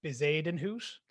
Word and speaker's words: Bezuidenhout: And my last pick is Christian Bezuidenhout.